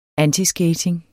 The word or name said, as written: antiskating